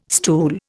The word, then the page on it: stool: Indian